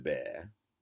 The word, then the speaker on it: Gilbert: Gilbert, with the French pronunciation Gilbert, is a common name still there in that part of France.